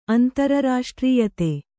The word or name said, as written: ಅಂತರರಾಷ್ಟ್ರೀಯತೆ